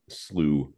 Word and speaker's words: slough: I had no idea what a slough was. – S-L-O-U-G-H, it means water in a ditch. – Slough, or it's spelled slough, which, as is it so often the case, there's no F's in it.